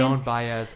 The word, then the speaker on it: Joan Baez: And Joan Baez, I don't know how to say her name. – Joan Baez.